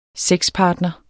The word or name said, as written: sexpartner